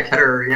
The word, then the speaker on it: Ketterer: Am I pronouncing the last name right? – Yeah, Ketterer. Yeah, cool. I mean, I think you pronounce it better than I do.